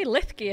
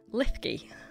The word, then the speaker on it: Linlithgow: So we are sitting in the gift shop of Linlith. – You could say Lithgay if it's easier. – Lithgay. – The Scots pronunciation. – So we're sitting in the gift shop of Lithgay Palace.